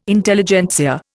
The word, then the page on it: Intelligentsia: Indian